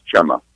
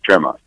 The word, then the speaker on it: Txema: And it's spelled T-X-E-M-A, right? – T-X-E-M-A, right. But they pronounce it in the Basque region, they pronounce those letters as Chema.